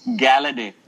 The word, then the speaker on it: Golladay: I know it's spelled Golladay, but they say it's Galladay.